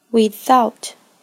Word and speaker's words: without: 热情、热忱，在发音上需要注意，nothing、 without 这两个单词的 th 都是咬舌音，将舌尖置于上下齿之间，轻轻咬合。